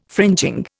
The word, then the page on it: fringing: Indian